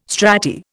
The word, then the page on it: strati: Indian